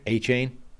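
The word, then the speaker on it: Achane: Devin, how do you say that guy's second name? – A-Chain? – A-Chain. That's how it's pronounced.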